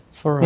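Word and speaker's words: Farran: Ferran, mm-hmm.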